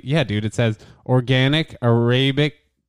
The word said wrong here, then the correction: Arabica: Arabica. What the shit does that say? – Arabica. – Oh my God, nice. That's how you pronounce that?